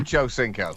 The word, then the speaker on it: Ochocinco: Ochocinco.